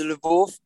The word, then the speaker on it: Lviv: I'm not sure how to pronounce it in English, but in Polish it's Lviv.